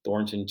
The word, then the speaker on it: Thornton: My name is spelled D-O-H-N, Thornton, T-H-O-R-N-T-O-N slash 30-M-I-N you do that,